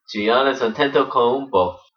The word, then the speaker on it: Giannis Antetokounmpo: It is Giannis Antetokounmpo. – And here's mine. Antetokounmpo. – Giannis Antetokounmpo.